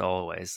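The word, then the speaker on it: Alvvays: Always spelled with two V's, right? – Always. – Yeah, that's the way I want to say it, but it is pronounced Always.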